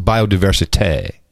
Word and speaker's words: biodiversité: When everyone's talking about it, you say, excuse me, it's pronounced biodiversité. Tay.